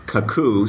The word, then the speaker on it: Cacoo: I believe it's pronounced Kakoo, C-A-C-O-O, and this is an online diagramming tool where you can create really simple diagrams.